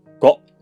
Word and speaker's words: Go: Go 其实正确的发音应该是 Go，来自日语啊，就是围棋的意思。日语中围棋叫做 Ego，简称叫 Go。